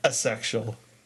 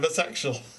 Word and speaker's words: asexual: How did he pronounce it the first time? – Asexual. – Short A instead of long. – Like, asexual what? Asexual. I'm asexual.